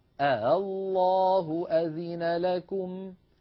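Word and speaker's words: آلله: أنا أريد أن أسهل همزة مفتوحة فأنطقها بين الهمزة والألف هكذا، الله أذن لكم، الله اسمعوا الصوت جيدا، ولا أقول الله، إن فعلت ذلك أكون قد نطقت همزتين،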